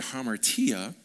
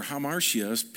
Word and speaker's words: hamartia: And we've learned over, you know, several teachings that the word sin in the Greek is the word hamartia, or hamartia, people pronounce it different.